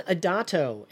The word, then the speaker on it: Adato: So my name is Leon Adato, and Adato is spelled A-D-A-T-O, and that's how you can find me.